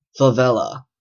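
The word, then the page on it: favela: Canadian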